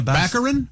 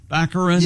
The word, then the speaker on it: Baccarin: Daredevil's girlfriend, Morena Baccarin. – Baccarin.